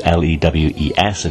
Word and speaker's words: Lewes: Uh, Lewes on the south coast, L E W E S. I've been told to pronounce it Lewes by somebody who lives there.